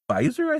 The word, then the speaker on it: Beiser: So Pendril was played by Brennan Beiser, I think is how you say his name. It's B-E-I-S-E-R.